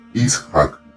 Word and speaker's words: Ishaq: así se pronuncia, Ishaq, no Ishaq, Basim Ibn Ishaq, si bien él nació en otra ciudad, la ciudad de Samarra, él pasa su adolescencia en Bagdad.